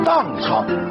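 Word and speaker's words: 宕昌: 荡昌，宝盖头底下一个石头的石，一块石头的石，宝盖头荡昌，甘肃的地名。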